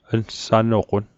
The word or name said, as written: nsanoqon